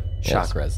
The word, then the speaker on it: chakras: The chakras. – The chungus. – Chakras, yes. Is how it's pronounced.